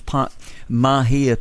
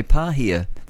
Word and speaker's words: Mahia: You're going to have that mainly near the coast, easing in the morning and clearing south of Mahia Peninsula. So I was going to say Pahia. That's dyslexia.